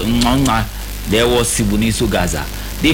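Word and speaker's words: Ngcongca: Before Anele Ngonga, why am I even saying Ngonga? They have a certain way that they say it in Zulu. Nganga, that is how they say it.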